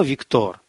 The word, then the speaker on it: Виктор: «И что?» «Вино, Виктор, вино!» Сергей произнес имя друга с ударением на последний слог.